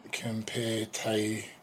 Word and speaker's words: Kempeitai: That said, the Kempeitai, if I'm pronouncing that right, what do you think? – Kempeitai. If I'm reading this Romaji correctly, Kempeitai.